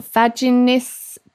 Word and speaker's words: vaginismus: Vaginismus? – Yes.